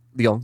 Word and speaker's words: Leung: L-E-U-N-G. – Yeah, just tackle it. – Do you think the E is silent? – L-E-U-N-G. – Like Hillary Long? – Long. – Yeah, that sounds good. – That feels right.